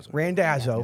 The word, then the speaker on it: Randazzo: Randazzo.